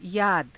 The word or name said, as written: yaada